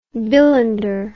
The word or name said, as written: bilander